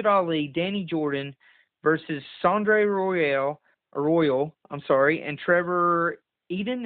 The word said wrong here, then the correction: Ion: Is that how you say his name? – Trevor Ion.